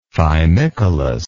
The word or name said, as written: fimicolous